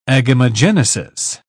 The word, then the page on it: agamogenesis: British English